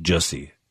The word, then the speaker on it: Jussie: From now on, you don't even get the U, because I guess it's supposed to be pronounced Jussie Smollett.